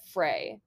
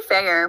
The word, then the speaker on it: Feyre: When I was reading this book, I always said Frey. I don't know why, but it's... But how do you say it? – I say fair, but...